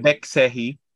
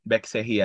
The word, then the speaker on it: Baek Se-hee: Um, it's basically the writer um the name of the writer is Baek Se-hee. I hope I did not mess the pronunciation up, Dren. Um, no, Be- that's not good. Bek Sehi. Bek Sehi yata.